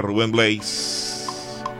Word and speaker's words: Rubén Blades: O como se conoce y como es la pronunciación correcta, Rubén Blades.